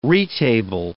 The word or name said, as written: retable